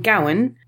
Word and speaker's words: Gawain: It is Christmas Day, and Gawain, that is how they predominantly pronounce his name in this film, so that's how I'm pronouncing it here.